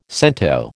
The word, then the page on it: cento: American English